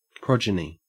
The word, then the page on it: progeny: Australian English